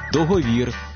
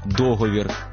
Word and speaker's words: договір: Коли, приміром, в українському слові є подвійний наголос, вибирають спільний із російським «завжди», замість «завжди», «договір», замість «договір»,